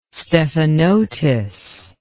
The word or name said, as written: stephanotis